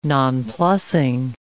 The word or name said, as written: nonplusing